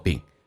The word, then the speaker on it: been: 第一个可以发成短元音叫做 been，第二种是可以把长元音 e 可以发成 a 这个发音变成 been，